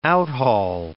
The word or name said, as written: outhaul